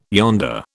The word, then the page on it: yonder: British English